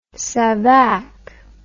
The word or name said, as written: Savak